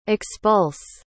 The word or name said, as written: expulse